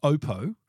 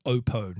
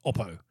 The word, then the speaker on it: Oppo: Well, Oppo, I used to call them Oppo, but I was told it's pronounced Oppo. I did travel with them to Barcelona earlier this year, and their Australian MD, actually, the CEO, Michael, told me, look, mate, it's Oppo, not Oppo.